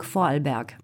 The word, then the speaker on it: Vorarlberg: Comment le prononce-t-on ? – Bon, moi je peux le prononcer qu'en allemand, donc Vorarlberg.